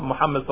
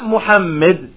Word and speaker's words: Muhammad: It's not with a kasra, it's with a fatha on the middle letter. Not Muhammad. Not Muhammad, but Muhammad.